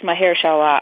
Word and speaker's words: Mahershala: I think it's Mahershala Ali.